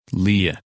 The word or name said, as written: Leah